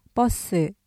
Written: 버스

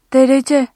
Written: derece

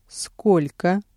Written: сколько

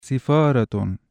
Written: سِفَارَةٌ (سِفَارَاتٌ)

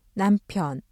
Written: 남편

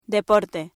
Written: deporte

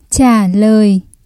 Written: trả lời

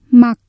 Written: mặc

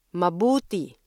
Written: mabuti

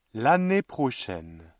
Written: l'année prochaine

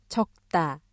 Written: 적다

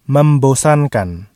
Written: membosankan

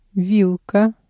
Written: вилка

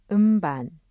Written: 음반

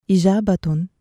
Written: إِجَابَةٌ

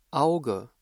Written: Auge

